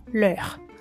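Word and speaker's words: leurs: Mas como o S não é pronunciado, o som das duas palavras é o mesmo, leur a menos quando você tem uma palavra começada com vogal na frente. E o S vai aparecer, Les amis, por exemplo.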